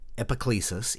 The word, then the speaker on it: Epiclesis: Epiclesis, E-P-I-C-L-E-S-I-S.